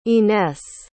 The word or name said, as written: ines